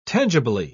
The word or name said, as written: tangibly